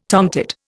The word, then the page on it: tomtit: Indian